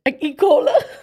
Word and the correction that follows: E. coli: E. coli.